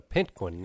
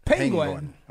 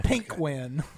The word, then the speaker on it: penguin: You just had a little emphasis on the wrong syllable. – It correctly then. – Penguin. – Okay, penguin. – There you go. – Not a pink wind.